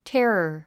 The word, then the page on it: terror: térə r; テェラァ